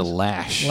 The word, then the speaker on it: Loesch: You know who Dana Loesch is? The head of the NRA. Oh, yeah, you're political. – She pronounces it Lash. – Is that how she pronounces it? – Yeah, there's no A in there.